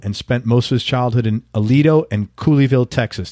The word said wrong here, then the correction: Colleyville: Did I pronounce that right? Cooleyville? – Colleyville. – Colleyville, yeah.